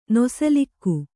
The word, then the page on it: ನೊಸಲಿಕ್ಕು: nosalikku